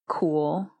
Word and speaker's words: Cool: Well, my name is Elspeth Cool, which is kind of crazy. – It's spelled like C O O L.